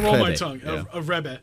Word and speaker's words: Rebbe: How do you pronounce it? – Oh, Rebbe.